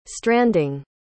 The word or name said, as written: stranding